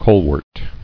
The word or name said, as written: Colewort